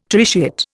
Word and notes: tritiate: Indian

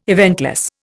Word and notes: eventless: Indian

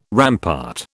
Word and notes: rampart: British English

